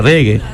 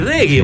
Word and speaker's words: reggae: De todo, ni se sabía pronunciar la palabra reggae. – Uh-huh. – Riggy, Rishi, Reggae, Reggae.